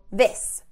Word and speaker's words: this: So make sure this, short I sound, ending in an S, is, this.